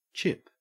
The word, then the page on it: chip: Australian English